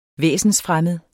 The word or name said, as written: væsensfremmed